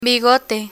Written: bigote

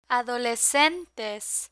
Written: adolescentes

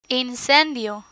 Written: incendio

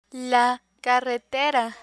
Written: carretera

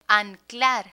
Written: anclar

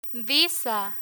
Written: visa

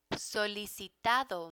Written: solicitado

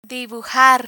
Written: dibujar

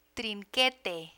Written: trinquete

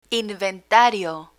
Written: inventario

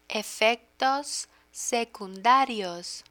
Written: efectos secundarios

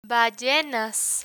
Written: ballenas

